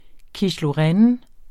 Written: quiche

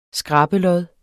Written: skrabelod

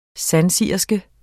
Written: sandsigerske

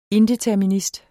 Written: indeterminist

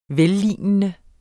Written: vellignende

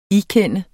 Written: ikende

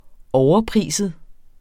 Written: overpriset

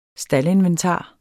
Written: staldinventar

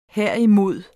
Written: herimod

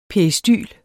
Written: peristyl

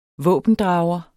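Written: VÅBENDRAGEREN

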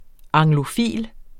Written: anglofil

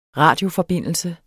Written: radioforbindelse

